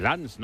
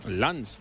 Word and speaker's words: Lens: Porque se dice Lens, ¿no? Lens, no se dice el Lens.